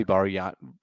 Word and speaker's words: Bargnani: I think it's Bargnani. – It's probably Barion. He's out of a league. – I think the G is silent in yeah, it's like, you're right.